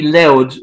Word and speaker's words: Louth: Well, it's pronounced County Loud, which would be L-O-U-D, would be the way you'd say it perfectly. – Yeah. – It's spelled L-O-U-T-H.